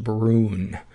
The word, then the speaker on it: Bruun: Brune from Norway, and it's spelled B-R-U-U-N, and I believe it's pronounced Brune.